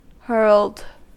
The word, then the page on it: hurled: American English